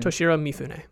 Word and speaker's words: Toshiro Mifune: Is that how you say his name? – Toshiro Mifune.